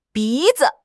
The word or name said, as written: bi2 zi5